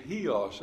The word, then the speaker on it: Chios: I used to call it Chios, it actually is Chios, I guess is the way, Chios is the way it's pronounced, where they spent the night, and most likely in that small strait between the mainland and the island.